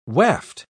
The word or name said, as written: waft